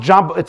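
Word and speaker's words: Jobbik: It's pronounced Jabuk, it's written Jabuk, it's pronounced Jabuk.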